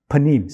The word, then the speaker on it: panim: Look, it means, in Hebrew, it's pronounced panim. Say panim, panim. It means, literally, face.